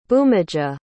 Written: bhumija

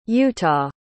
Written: utah

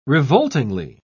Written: revoltingly

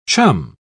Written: chum

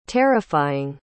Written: terrifying

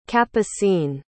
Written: capucine

